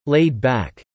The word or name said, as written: laid-back